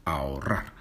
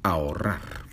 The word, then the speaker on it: ahorrar: Ahorrar. – Y con una H intercalada que no se pronuncia. Por eso es una palabra difícil de pronunciar. Ahorrar.